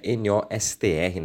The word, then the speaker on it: Nostr: seria a Nost é, NOSTR, né, Nostr, acho que se pronuncia assim.